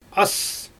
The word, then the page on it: as: Xyzyl